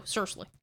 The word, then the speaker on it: SRSLY: So, and when we say it's called Seriously, it's spelled S-R-S-L-Y. – Yes. – So, Seriously.